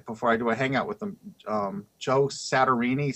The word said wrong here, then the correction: Satriani: Satriani. – Satriani, yeah.